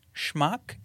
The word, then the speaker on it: schmuck: Schmuck? Did I say that right? Schmuck? – Yeah, schmuck. Yeah, you know.